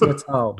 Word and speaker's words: Natal: It's Natal, you say. – I believe it's Natal. Yeah.